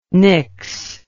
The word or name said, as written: nyx